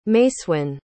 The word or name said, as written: macewen